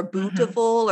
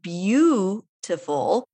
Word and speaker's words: beautiful: And if you look at it just written, you might say it looks like beautiful or bootiful or something like that. And I think like that's such a common word that people learn very early. So they don't even think about the pronunciation. But if you listen to it, beautiful, there's a like this mysterious Y sound that just appears in there.